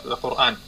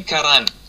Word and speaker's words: Аль-Куран: Не Коран. К сожалению, то есть по-русски говорят Коран. Коран — это неправильно. Пытайтесь, то есть, правильно говорить. То есть Аль-Куран. То есть, если без артикуля Аль, тогда говорим Куран. Куран. Поняли? То есть не Коран, Куран. То есть шейх сказал, Аллах Азза ва Джаль называл некоторые из этих книг.